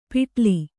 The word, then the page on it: ಪಿಟ್ಲಿ: kannada